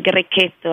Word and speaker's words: Grechetto: Oh, it's pronounced Grechetto? – Yes, the Grechetto, it's best pronounced in Italian, Grechetto actually.